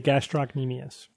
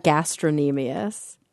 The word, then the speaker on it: gastrocnemius: The tibialis anterior and the gastrocnemius. – Right. – It's so interesting. We always pronounce that gastrocnemius. We didn't pronounce the k.